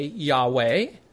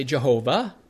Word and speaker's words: Yahweh: We say Yahweh. The Germanic pronunciation, they say Jehovah, which is fine, I guess. Yahweh is more accurate.